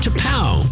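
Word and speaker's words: T'Pau: T-apostrophe-P-A-U, pronounced to pow.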